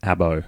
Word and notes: abo: Australian English

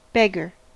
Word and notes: beggar: American English

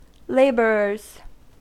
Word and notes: laborers: American English

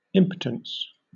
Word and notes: impotence: British English

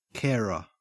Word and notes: carer: Australian English

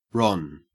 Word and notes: ron: Australian English

